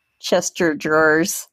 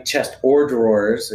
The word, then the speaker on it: chest of drawers: We just wanted to start a bar so that we could call it Chester Drawers. Because nobody in Utah calls it a chest of drawers. It's always Chester Drawers. – Oh, that's funny. And if you said it right, chest or drawers, it could actually even have a little bit more funnier, you know.